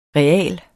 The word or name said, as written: real